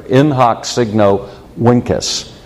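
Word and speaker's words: In hoc signo vinces: In hoc signo vincus, which is the way you pronounce a V in classical Latin.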